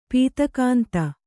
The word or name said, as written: ಪೂತಿಗಂಧಿ